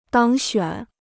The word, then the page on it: 当选: dāng xuǎn